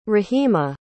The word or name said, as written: raheema